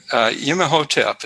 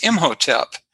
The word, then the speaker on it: Imhotep: Well, actually, Yemehotep is how you spell it in hieroglyphics, but how it's been pronounced traditionally is Imhotep.